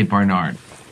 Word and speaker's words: Bernard: Am I saying that right? Is it Bernard? – That's kind of how I say it. But cool. – If I want somebody to spell it, I say Barnard. – Barnard. – Because there's an A there.